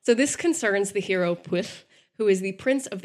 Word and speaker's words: Pwyll: Pwyll is exactly I I swear that's how you pronounce it. It's spelled P W Y L L. Pwyll.